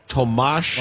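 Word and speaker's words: Tomáš: Tomas? – Tomas. – Mosh. – Yurko. – Mosh. – Tomas. – Like a mosh pit? – Yes.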